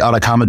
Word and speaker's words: Atakama: And Atacama is spelled A-T-A-K-A-M-A. We're named after the Atacama Desert in Chile, which is spelled with a C. But we spell our name with a K.